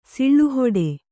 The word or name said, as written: ಸಿಳ್ಳುಹೊಡೆ